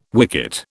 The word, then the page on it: wicket: British English